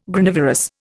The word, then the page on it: Granivorous: Indian